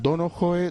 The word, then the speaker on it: Donohoe: Que depende, porque hay algunos que dicen Donohoe porque él es irlandés. Entonces, como el apellido es irlandés, al parecer la H con la E sí que es verdad que habría que pronunciarla. Habría que decir algo así como Pascual Donohoe, algo así como muy suave, ¿no?